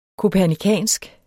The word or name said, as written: kopernikansk